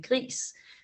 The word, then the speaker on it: Gris: Og mine seneste, mine seneste to, det har været, øh, jeg har spillet et spil, der hedder Gri, det staves øh, gris, men jeg formoder, man skal udtale det lidt mere fransk og sige Gri, fordi at hun er, det er sådan en, en lille kvinde, som starter med at være grå.